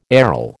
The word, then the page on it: aryl: American English